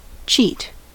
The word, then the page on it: cheat: American English